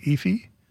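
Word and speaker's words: Aoife: I went to see, I'm going to try and pronounce her name correctly, Efi O'Donovan. It's A-O-I-F-E. It's the Gaelic spelling.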